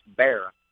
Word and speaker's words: Barat: Well, it looks like Barat, but it's kind of pronounced Barra.